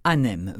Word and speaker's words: Ahnhem: Ça s'écrit A-H-N-M. Pardon, je recommence. A-H-N-H-E-M, Anem.